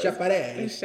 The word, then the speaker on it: Schiaparelli: Schiaparelli.